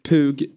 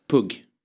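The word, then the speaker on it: Pugh: Pug Rogefeldt. – Där måste jag rätta uttalet faktiskt. – Ja. – Pug. – Sa jag Pug? – Pug, sa du.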